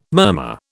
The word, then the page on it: murmur: British English